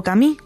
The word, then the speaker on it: Camus: Narrado por nuestros compañeros de Un libro, una hora, inaugura la primera novela del escritor argelino francés Albert Camus, o Camus, como se pronuncia en su idioma natal.